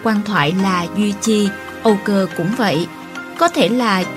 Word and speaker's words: Âu Cơ: Ngu cơ chính là Âu cơ, Người Việt gọi Âu Cơ, Quảng Đông và Mường phát âm như Ngô Cơ.